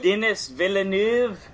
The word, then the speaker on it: Denis Villeneuve: Oh, Denis Villeneuve.